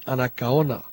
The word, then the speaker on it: Anacaona: He heard Radio Anacaona January 31st at 0220, strong with Latin music and numerous IDs.